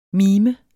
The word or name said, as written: mimer